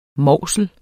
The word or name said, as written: mausel